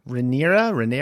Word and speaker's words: Rhaenyra: Rhaenyra? – Yes.